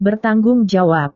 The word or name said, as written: bertanggung jawab